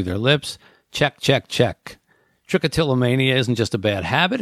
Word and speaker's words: trichotillomania: Trichotillomania. Am I pronouncing that right? – Trichotillomania. – Trichotillomania, they call it.